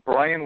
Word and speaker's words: Bryan: It's Brian with a Y, so B-R-Y-A-N. And then Eisenberg, E-I-S-E-N-B-E-R-G.